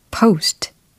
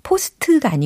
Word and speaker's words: post: post, post. 이렇게 발음하시면 됩니다. p 포스트가 아니고 post, post, post.